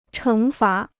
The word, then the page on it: 惩罚: chéng fá